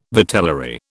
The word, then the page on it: vitellary: British English